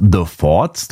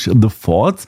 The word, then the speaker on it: The Forts: The Forts, da muss man aufpassen, auf Deutsch nicht aussprechen. Das ist Englisch, The Forts, arbeiten mit einem wechselnden Kreis von Musikern und bleiben dabei ihren rauen Lo-Fi-Sound treu.